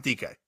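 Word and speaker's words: Dike: I want to call him Dyke. Young. I want to say Dyke is the last name, but yeah, they pronounce it DK.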